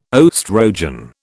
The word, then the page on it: oestrogen: British English